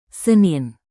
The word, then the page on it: Senin: Indonesian